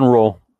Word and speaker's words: Ruh: Daniel said she was working with Dawn Rowe. I'm not how you say it. – Right. – R-U-H. And I believe it was his book. – Right, that's it. Dawn Rowe.